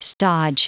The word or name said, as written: stodge